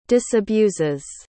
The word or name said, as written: disabuses